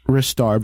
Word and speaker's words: Ristar: Well, let's just say that almost five guys are confirming that it is pronounced Ristar, but they hate the fact that they have to pronounce it that way. – It is right. Ristar. It is. If you spell it R-I-S-T-A-R, it's Ristar.